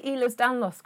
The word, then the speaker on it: Ehlers Danlos: Did I say Ehlers Danlos correctly? – I think you did.